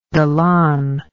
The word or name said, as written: Dallan